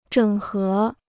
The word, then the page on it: 整合: zhěng hé